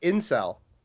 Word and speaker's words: incel: This is an Inkel origin story, is what you're telling me here. – Whoa, whoa, whoa, whoa. Time out. It's pronounced incel.